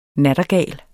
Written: nattergal

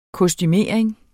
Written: kostumering

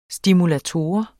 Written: stimulator

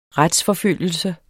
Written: retsforfølgelse

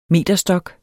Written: meterstok

